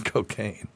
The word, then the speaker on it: Cocanne: It was spelled C O C A N N E, but it was, it was, pronounced cocaine.